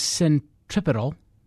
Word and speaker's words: centripetal: Cultural conservatism is centripetal. Wait, did I pronounce that right? – Yeah.